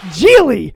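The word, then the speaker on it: Gigli: Was it Gidgley? – Geely was the name of that one. Geely.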